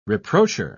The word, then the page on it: reproacher: British English